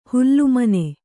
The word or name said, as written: ಹೊಲೆಮನೆ